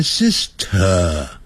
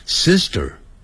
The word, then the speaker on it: Sister: 好，千万别念 Sister，虽然是 T 的音呢、啊，可是 S 在 S 后头啊，那个 T 又念成德，像德国的德的音，Sister， Sister， Sister， Sister， Sister。